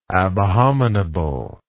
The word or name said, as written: abhominable